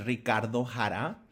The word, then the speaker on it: Ricardo Jara: Well, my name is Ricardo Jara. Emphasis on the ho, on Ricard ho.